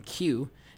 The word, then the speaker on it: Cuee: Next up, we're going to hear music from Q. That's spelled C-U-E-E.